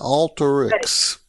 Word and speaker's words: Alteryx: Alteryx Inc. Is that how you say it? Alteryx. Yeah. Yes.